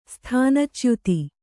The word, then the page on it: ಸ್ಥಾನಚ್ಯುತ: sthāna chyuta